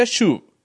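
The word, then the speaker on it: shuv (שׁוּב): Un importante término hebreo traducido como arrepentirse en el Antiguo Testamento se pronuncia shuv y significa darse la vuelta, regresar a la dependencia de Dios.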